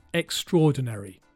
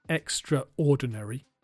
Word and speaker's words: extraordinary: It's pronounced extraordinary, not extraordinary. Extraordinary.